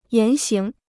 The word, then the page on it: 言行: yán xíng